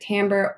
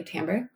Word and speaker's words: timbre: You can technically pronounce it timbre or timber, but it's a French word. So I think we like to say timbre, but say it however you want.